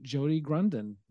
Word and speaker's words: Jody Grunden: And with that, I give you Jody Grunden.